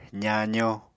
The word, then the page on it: zaazo: apatani